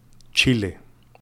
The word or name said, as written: Chile